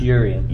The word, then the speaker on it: Yerian: I'll spell it. It's John. Y-E-R-I-A-N. – One more time. – Y-E-R-I-A-N. – You can try it if you want. I'm not embarrassed. – Byron? – It's Urian.